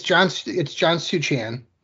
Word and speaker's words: John Suchan: It's John Suchan.